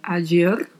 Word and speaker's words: ayer: A ger tem lá Y seguida da letra E. Portanto, a pronúncia pode ser a agir, ayer, asher, dependendo da região. E não se esqueça do vibrato do R ao final.